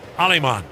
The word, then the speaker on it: Alemán: You spell the last name A-L-E-M-A-N. Looks like Aleman, but it's pronounced Alemán.